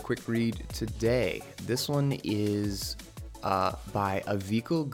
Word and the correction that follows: Avichal: Garg, and or Avichal, I think is actually how you pronounce it.